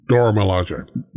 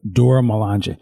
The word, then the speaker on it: Dora Milaje: How do you say them? The Dora Milaje? – Dora Milaje. – Dora Milaje.